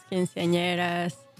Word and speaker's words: quinceañera: And it's for special events like weddings, quinceañeras, any birthday party, right, corporate events. – Help me out. Educate me. A quinceañera is not quinceañera. Am I pronouncing that right? – Quinceañera. – Quinceañera.